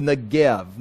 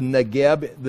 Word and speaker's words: Negev: Now, here it's the Negev, the Hebrew word, the bet. It can be pronounced the vet or bet, you know, either a B or a V, depending on the Dagesh.